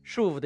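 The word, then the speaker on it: shuv (שוב): 摩西用的希伯来文是 shuv，发音听起来就像中文的舒服。 shuv 的意思就是转身回来的意思，这个词在希伯来文中是一个快乐词，是一个听了会让人开心的词。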